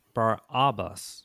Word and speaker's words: Barabbas: Now, his name, let me pronounce it a little differently. Bar-Abba, it means son of the father.